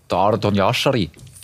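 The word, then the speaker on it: Ardon Jashari: Heute ist der große Yashari. So spricht man ihn aus, oder? – Ich sag Ardon Yashari. Ich glaube, der Schweizer würde es so sagen.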